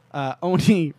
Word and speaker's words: Oni: Oni rhymes with another company that Microsoft is competing against.